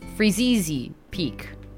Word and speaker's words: Freezeezy Peak: And then after that, I'm realizing that I've been saying this name wrong of this song. I've been saying Freezy Peak. It's Freezeezy Peak.